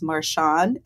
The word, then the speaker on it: Martion: It was started by a man named Patrick, and I want to say this right. I believe it's Marchion, M-A-R-T-I-O-N.